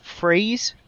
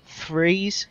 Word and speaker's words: Threes: Well, I've been playing a game you might have heard about called Freeze, or Threes, to say it properly.